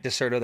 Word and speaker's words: Dexerto: It's not Deserto, it's Deserto. So thank you. Deserto. Thank you so much for saying that correctly. And shout out to everybody over at Deserto that said, hey, you're saying it wrong.